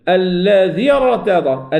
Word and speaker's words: الذي ارتضى: إذا هنا تحذف الياء مكتوبة لكن لا تنطق، فقول الذي، الذي ارتضى، الذي ذال، ذال مكسورة مباشرة مع الراء، الذي ارتضى لهم، الذي ارتضى.